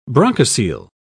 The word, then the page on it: bronchocele: British English